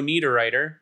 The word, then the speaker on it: Niederreiter: Joe Veleno stomped on the leg of Swiss captain Nino Niederreiter, which I have in my notes how I spelled Niederreiter. N-E-D-E-R-I-T-T-E-R.